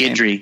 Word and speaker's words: Guidry: How do you pronounce that guy's Guidry. – Name? Guidry. – Yeah, Guidry. Oh, dude, you nailed it.